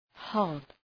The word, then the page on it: hob: hɒb